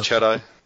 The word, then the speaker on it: Chatto: How do you say his last name? Is it Chatto or Chat? – I've always said just Chatto. Yeah. – Chatto, so we'll call it, yeah, Keith Chatto. – He's an Australian, and Australian names are just pronounced how they're written. His name is is written Chatto, that's how we say it.